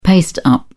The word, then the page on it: paste-up: British English